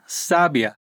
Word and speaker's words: sábia: Pensem na escrita S-A-B-I-A. Se eu pedir para você juntar as letras, você dirá sabia. O B é a mais forte mas eu posso colocar um acento no primeiro A e transformá-la em sábia. Ou até posso acentuar a última sílaba e teremos aquele pássaro, o sabiá.